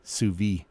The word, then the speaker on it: sous vide: But anyway, the thing about sous vide is that, and I know you're not supposed to pronounce the D in sous vide. – That's right. – Sous vide.